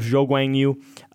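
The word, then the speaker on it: Zhou Guanyu: I said it would be Guo Wang Yu. I can't say his name, but Zhou, you know what I mean. – Yeah, I know what you mean. – Zhou Guang Yu.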